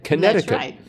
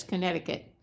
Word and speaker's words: Connecticut: Are people generally aware of the fact that that middle C in Connecticut is silent? Because that was a revelation to me. It's just like, there's a C in there, but you don't say it. Connecticut.